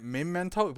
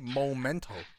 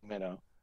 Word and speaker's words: Memento: But isn't it Memento? Because it's M-E instead of M-O. I don't know if everybody pronouncing it incorrectly or it's just me. People like to say Momento.